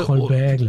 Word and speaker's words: Colbert: Der ist bei der Late Show mit Stephen Cole, kann ich ihn aussprechen, im Gebäude. – Colbert, genau.